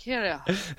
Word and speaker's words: Kyrgios: I'm pretty sure it's pronounced Kyrgios.